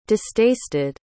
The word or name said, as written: distasted